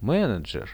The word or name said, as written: menedzher